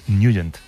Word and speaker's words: Nugent: Hombre, siempre lo hemos llamado en el barrio Ted Nugent o Ted Nugent. – Claro, ¿verdad? Yo era de Ted Nugent. – Pero la pronunciación correcta es Nugent.